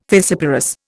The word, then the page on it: fissiparous: Indian